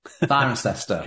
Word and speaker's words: Cirencester: Cirencester? I may not pronounce that right. – Cirencester.